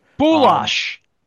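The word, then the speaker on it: Bulaich: Connery was more, what do we, what, Norm Balayek, forgive me if I butcher the name. – Boulash. – Boulash, okay, yeah, because I only read it, never heard it because he retired before my time, but that's what I mean.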